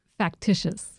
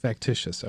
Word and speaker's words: Factitious: Factitious. – Factitious.